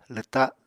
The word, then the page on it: lwta: apatani